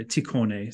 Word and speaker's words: Tikhon: This is Tikhon or Tikhone, sometimes it's pronounced.